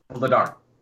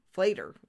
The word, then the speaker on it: Vladar: And somebody uh, from Canada was like, Later. Flater. That's his name. Flater. I'm like, mm-mm, that is not how to say it.